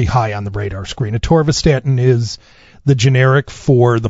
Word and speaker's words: atorvastatin: atorvastatin. – Yeah. – Is that how you pronounce it? – Yeah, generic atorvastatin.